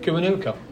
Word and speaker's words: Kiwanuka: Michael Kiwanuka. – Kiwanuka.